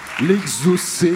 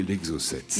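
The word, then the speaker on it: exocet: Ça s'écrit bien E-X-O-C-E-T. L'exocé, ou si vous voulez, l'exocète.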